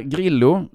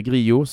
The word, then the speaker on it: Grillo: Emiliana Grillo, det ska uttalas så, inte Grillo som är det allmänt spanska, argentinaren, gillar ju den här tävlingen, kom femma förra året.